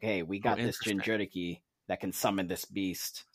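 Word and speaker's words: Jinchuriki: So in Naruto, all the nations have what's called a Jinjuriki, or Jinjuriki, if you're going to try to pronounce it Japanese.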